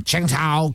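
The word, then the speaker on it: Qingdao: And then, Angela says, you are pronouncing Qing Tao correctly, despite putting on the bad accent. – So Angela Lala. I just listened to the pronunciation. – And? – It's right.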